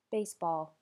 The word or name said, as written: baseball